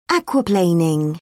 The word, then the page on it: aquaplaning: British English